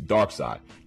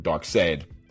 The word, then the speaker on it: Darkseid: which is Darkseid. Darkseid, what Dark said, you know, people say his name wrong all the time, but Dark said actually is one of the, you know, he's real big, hulking, gray-skinned dude.